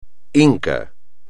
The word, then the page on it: inca: espagnol